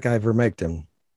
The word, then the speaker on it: ivermectin: He took ivermectin. I don't know if I'm saying that right. – Ivermectin. – Close enough. – I think there's an N on the end there. Ivermectin.